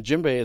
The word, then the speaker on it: djembe: It is spelled D-J-E-M-B-E, and the djembe is pronounced djembe.